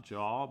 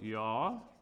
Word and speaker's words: Yah: And this is pronounced not Jah, but Yah, which is short for Yahweh, which is Lord.